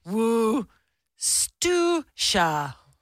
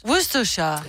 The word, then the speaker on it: Worcestershire: How is Worcestershire actually pronounced? It's pronounced Worcestershire. Worcestershire.